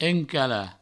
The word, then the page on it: encalar: encala